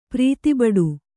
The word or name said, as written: ಪ್ರೀತಿಬಡು